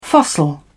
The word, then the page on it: fossil: British English